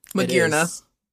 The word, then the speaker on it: Magearna: Magearna.